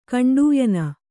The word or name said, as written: ಕಂಡೂಯನ